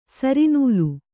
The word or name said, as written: ಸರಿನೂಲು